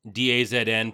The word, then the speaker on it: DAZN: DAZN, pronounced DAZN. That's not English.